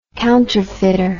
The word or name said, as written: counterfeiter